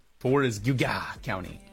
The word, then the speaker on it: Geauga: Wait, here's the thing. It's actually Giga.